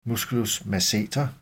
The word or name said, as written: m. masseter